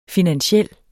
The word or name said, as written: finansiel